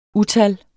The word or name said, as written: utal